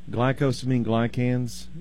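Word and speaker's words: glycosaminoglycans: There is a substance produced in the human body called glyco. I've already butchered it, so let me start over here. Glycosamine glycans, GAGs.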